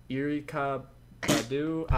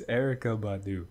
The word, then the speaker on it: Erykah Badu: I'm sorry if you're a fan of them, but Erykah Badu. I butchered it. – Erykah Badu. – That's not how you spell Erykah. Cap. What? Hold on. – It's Erykah Badu.